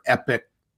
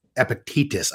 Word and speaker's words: Epictetus: Epictetus? – Epictetus, I guess.